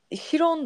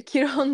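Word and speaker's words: Chiron: Valla Şiron da olabilir, Kiron da olabilir, Kayron da denilebiliyor.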